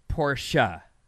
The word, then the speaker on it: Porsche: Porsche is German, and in German, you pronounce every letter. It's Porsche.